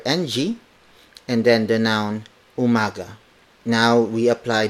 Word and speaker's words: umaga: It's Magandang umaga.